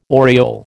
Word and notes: aureole: American English

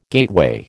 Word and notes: gateway: American English

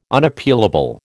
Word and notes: Unappealable: American English

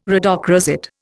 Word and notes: rhodochrosite: Indian

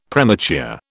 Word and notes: premature: British English

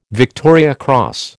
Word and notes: Victoria Cross: American English

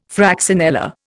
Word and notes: fraxinella: Indian

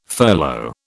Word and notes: furlough: British English

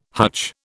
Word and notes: hutch: British English

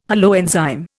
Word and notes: holoenzyme: Indian

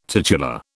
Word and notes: titular: British English

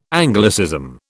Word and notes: Anglicism: British English

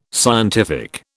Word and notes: scientific: British English

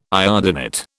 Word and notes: iodinate: British English

